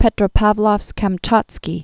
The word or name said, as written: Petropavlovsk-Kamchatski